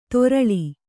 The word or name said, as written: ತರಳ